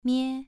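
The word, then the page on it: mie: tone 2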